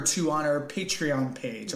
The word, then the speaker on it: Patreon: Am I saying that correctly? – That's it. Patreon? I think that's it. Yeah, not what were you calling it? Palladium? – Patreon. – No, you're calling it something. Yeah, Palladium Con.